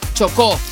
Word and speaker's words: Chocó: Chocó.